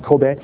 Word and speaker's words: Kobe: There's a city called Kobe, you know, and you don't pronounce it Kobe, you pronounce it Kobe.